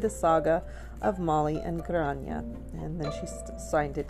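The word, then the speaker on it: Gráinne: Grania is pronounced, it's spelt G-R-A-I-N-N-E.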